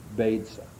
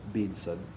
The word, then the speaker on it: Beza: and called as its first rector Beza, or Beza, pronounced a number of ways, B-E-Z-A is its spelling.